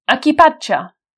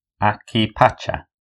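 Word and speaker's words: equipaje: This is equipaggio. – Equipaggio. – Yeah, difficult. Equipaggio, also because the spelling is a little bit strange. We've got this Q-U I there, you know, the silent U. So it's equipaggio.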